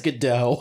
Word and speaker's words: Gadot: What are your thoughts on Gal Gadot, or Gal Gadot, or however you pronounce her last name? – I think it's Gadot.